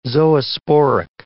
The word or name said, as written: zoosporic